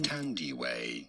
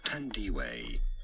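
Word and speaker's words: Thandiwe: Tandiway, not Fandiway, but rather Tandiway Newton.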